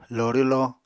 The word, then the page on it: loorwlo: Apatani